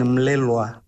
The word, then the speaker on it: Melelgua: Ayúdeme con su apellido, ¿cómo tengo que pronunciarlo bien? – Y has hecho muy bien, Melelgua. – Melelgua. – Has hecho muy bien, sí.